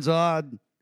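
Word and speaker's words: Zahnd: Brian Zod would say it like this, and I was told to say his name correctly by Joel Looney, who said, the way you say his name is like pond with a Z.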